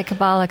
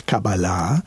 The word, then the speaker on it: Kabbalah: So in Hebrew, it's Kabbalah. – Yeah. – And in English, it's usually Kabbalah. – Right. – So, but either one is correct, Kabbalah or Kabbalah. – Well, I prefer to say it correctly, but I'm aware that it's usually said, now I can't remember, it's usually said Kabbalah, right? – Yeah, that would be a more English-sounding acceptable pronunciation.